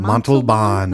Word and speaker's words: Montalban: Montalban.